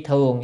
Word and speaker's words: thường: Phục dĩ tứ thiên, à, tứ bát vạn tứ thiên y thưởng, y thường nhé, chữ này đọc là thường,